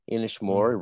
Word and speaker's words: Inishmore: Inishmore rhymes with whore.